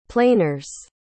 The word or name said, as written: planers